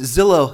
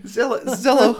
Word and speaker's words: Zillow: Zillow Hugs. And they're going to have a nice grandma that's going to give you a big hug. – I know. After all, it rhymes with pillow.